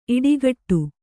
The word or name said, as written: ಈಡುಗೋಡೆ